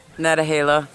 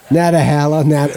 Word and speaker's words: Nantahala: So Nantahala, am I pronouncing that right? Is it Nantahala? – I pronounce it Nantahala. – Nantahala. – Nantahala.